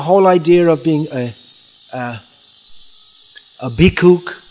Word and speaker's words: bhikkhu: the pronunciation of ksha is ka, as is still in Bengal, and in so Maithili, that area of India, so bhikshu became bhikkhu.